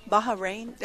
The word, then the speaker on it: Bahrain: Yeah, so he's in Bahrain. Am I saying that correctly? – Yeah.